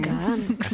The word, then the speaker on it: Kahn: ¿Cómo es la pronunciación? – Kahn. – Sí, suena más malo. Kahn.